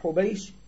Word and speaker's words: حبيش: عن عائشة رضي الله عنها أن فاطمة بنت أبي حبيش بضم المهملة وفتح الموحدة بعدها مثنى تحتية ثم شين معجمة ابن عبد المطلب، يبقى أبو حبيش ابن عبد المطلب.